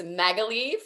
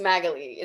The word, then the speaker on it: Magalie: It's Magalie for anyone who is confused. It gets pronounced so many different ways, which I'm totally, I'm fine with, but it's Magalie is the pronunciation.